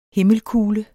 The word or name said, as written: himmelkugle